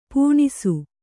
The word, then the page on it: ಪುಣ್ಸೆ: puṇse